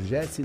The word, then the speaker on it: Jesse: Quem quiser me seguir nas redes sociais, só procurar lá por Jesse Navarro. No Instagram eu tô como Jesse Navarro 21, mas é importante você saber que o GS se escreve com J, J-E-S-S-E.